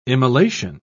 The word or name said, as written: immolation